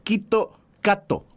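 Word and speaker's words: Kito Kato (キットカット): La pronunciación japonesa de Kit Kat es Kito Kato.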